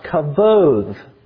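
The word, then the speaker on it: kavod (כבוד): The Hebrew word for glory is pronounced kavod.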